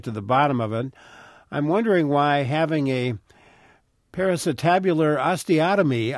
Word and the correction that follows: periacetabular osteotomy: Parasitabular osteotomy, is that correct pronunciation? – Well, yeah, periacetabular osteotomy.